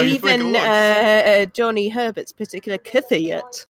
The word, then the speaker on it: Kvyat: Anyway, if you want to pronounce it authentically for the coming years, it's kind of pronounce it like you're swallowing it. So like it's in the back of your mouth. Kvyat.